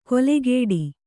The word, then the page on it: ಕೊಲೆಗೇಡಿ: kolegēḍi